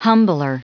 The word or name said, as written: humbler